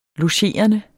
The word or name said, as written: logerende